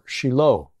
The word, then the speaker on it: Shiloh: Shiloh, or Shiloh, as it's pronounced in Hebrew.